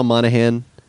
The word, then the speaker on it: Monaghan: All right, so it's also starring Michelle Monaghan. Yeah, that's how you say her name.